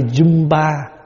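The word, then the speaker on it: Jambu: Tiếng Phạn đọc là cây Jumba.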